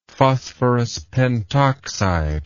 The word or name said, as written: phosphorus pentoxide